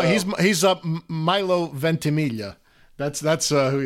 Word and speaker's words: Ventimiglia: I think in Italian it's Ventimiglia, which means 20,000.